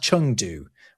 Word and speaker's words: Chengdu: I say Chengdu, I think it's pronounced Chengdu. When you see Eng in Mandarin, it's actually an ung sound.